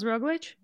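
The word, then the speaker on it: Roglič: And we saw in the men's race the same thing where I always say his name wrong, Roglic. – Primoz Roglic? I don't say it right either, but it's close enough. – Yeah, that actually sounded pretty good.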